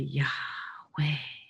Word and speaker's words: Yahweh: and it's actually best interpreted as Yahweh. And it's actually Yahweh. It's breath itself.